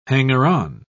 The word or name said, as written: hanger-on